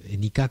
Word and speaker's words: Nica: Nica, quand on le prononce vraiment à la façon hispanique, ça a un petit côté sec, claquant, comme ça.